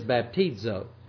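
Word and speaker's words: baptizo (βαπτίζω): But the word in the original language, in the Greek language, actually is baptizo. That's how you pronounce that.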